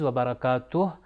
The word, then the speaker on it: وبرکاتہ: السلام علیک ایہا النبی ورحمۃ اللہ وبرکاتہ, اس کے اندر بھی دوبارہ آخر میں دیکھیے وبرکاتہ تھا کہ جس کا تلفظ وبرکاتہ, یعنی آخری ہا کو ہم لوگوں نے بیان کرنا ہے تاکہ سننے والے کو ایسا لگے کہ آخر میں ایک ہا موجود ہے.